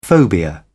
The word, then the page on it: phobia: British English